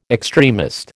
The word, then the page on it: extremist: American English